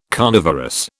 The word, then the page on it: carnivorous: British English